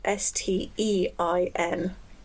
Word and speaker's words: Stein: It's it's blood stain, but stain is spelled S T E I N, so Stein.